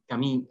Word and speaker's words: Camus: Bueno, Camus se pronuncia, pero se escribe Camus eh, franceses, muchos de ellos.